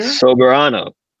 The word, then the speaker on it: Soberano: I said this wrong earlier, Sobernero Jr.? – Soberano. – Soberano. Oh, yeah. Sober, like as in sober as in I don't drink.